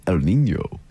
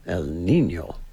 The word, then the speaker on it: El Niño: You have to say it like this, El Niño. – El Niño.